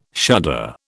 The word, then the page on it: shudder: British English